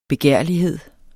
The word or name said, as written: begærlighed